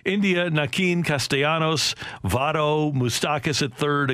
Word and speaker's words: Naquin: even though everybody calls him Naquin, including him, but apparently, Joey Votto said, no, his name is supposed to be pronounced Nakeen.